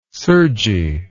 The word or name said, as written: surgy